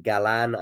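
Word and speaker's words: Galán: Galan, I think, is the name, and of the emphasis, I believe, being on the second syllable, from Colombia.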